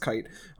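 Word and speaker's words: Kaite: But the flashback with Kate shows that his father wants him to be a kind of person that does things for others, but Kite, sorry, it's spelled weird. It's K-A-I-T-E, but it's pronounced Kite.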